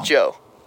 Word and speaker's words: Djo: Joe.